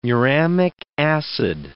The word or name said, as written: muramic acid